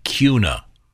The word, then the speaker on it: Kuna: Tom, in case no one else explained, KUNA Idaho is pronounced Cuna.